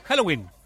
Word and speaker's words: Halloween: ¿Cómo se dice? ¿Halloween? Halloween. Ahí está, Halloween o Noche de Brujas.